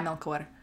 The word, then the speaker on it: Melchor: It's Melchor? really good. – It's really good. – Yeah, Melchor.